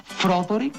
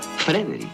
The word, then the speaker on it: Frederick: ¿Dice usted también Froderick? No, Frederick. ¿Y por qué no es Froderick Frankenstein? ¿Por qué no? Es Frederick Frankenstein.